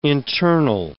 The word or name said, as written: internal